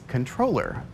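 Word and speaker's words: comptroller: Even though the word is spelled with a P, the correct pronunciation is controller.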